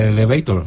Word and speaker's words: elevator: El elevator, ¿no? En inglés. – muy bien pronunciado.